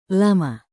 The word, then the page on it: lama: Indonesian